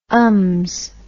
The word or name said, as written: ums